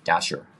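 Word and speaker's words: Dashjr: So the, the BIP maintainer is Luke Dash, uh, Dasher, is how it's pronounced.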